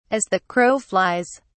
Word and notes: as the crow flies: British English

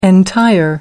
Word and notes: entire: American English

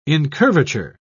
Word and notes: incurvature: British English

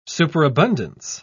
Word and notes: superabundance: British English

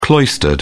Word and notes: cloistered: British English

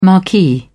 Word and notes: marquee: British English

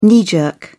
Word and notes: knee jerk: British English

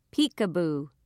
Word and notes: peek a boo: British English

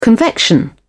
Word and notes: convection: British English

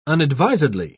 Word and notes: unadvisedly: British English

